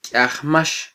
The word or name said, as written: kyiajmax